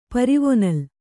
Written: ಪರಿವೊನಲ್

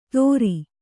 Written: ತೋರಿ

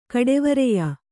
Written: ಕಡೆವರೆಯ

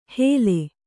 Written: ಹೇಲೆ

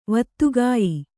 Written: ವತ್ತುಗಾಯಿ

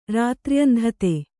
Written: ರಾತ್ರ್ಯಂಧತೆ

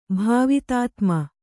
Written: ಭಾವಿತಾತ್ಮ

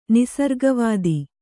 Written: ನಿಸರ್ಗವಾದ

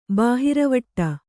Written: ಬಾಹಿರವಟ್ಟ

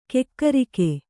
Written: ಕಕ್ಕರಿಕೆ

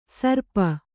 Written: ಶ್ರಾಪ

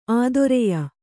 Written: ಆದೊರೆಯ

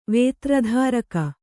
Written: ವೇತ್ರಧಾರಕ

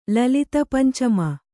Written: ಲಲಿತಪಂಚಮ

